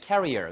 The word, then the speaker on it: carrier: C-A-R-E-E-R은 career, 경력이란 뜻입니다. 우리가 career, career 하는 것은 C-A-R-R-I-E-R이라고 해서 뭐 나르는 거라든가 아니면 뭐 균의 보균자라든가 예, 이런 걸 갖다가 날 것, 들 것 이런 걸 carry라고 하잖아요. carrier, 그렇죠? 그래서 그런 걸 얘기하고요. 이거는 career, 스펠링도 다르고 발음도 다릅니다. 철자도 다르고요.